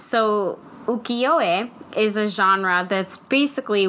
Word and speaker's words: ukiyo-e: And then that genre that that falls into is ukiyo-e.